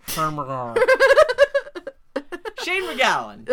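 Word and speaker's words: Shane MacGowan: If you pronounce it like he does, it's... Shane McGowan.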